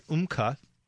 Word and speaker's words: Umcka: In the last segment, we were discussing this product, UMCA, and it's spelled U-M-C-K-A.